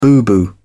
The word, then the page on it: boo-boo: British English